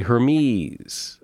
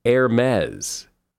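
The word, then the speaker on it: Hermès: And Hermes. A lot of people say Hermes. I've always said Hermes. It's actually Hermes, as in Pez.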